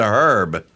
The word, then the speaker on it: herb: So you take Herbert and turn it into Herb. – So on those grounds, you can't pronounce the H at the beginning of Herb? – I have never heard anyone say, I have a herb garden.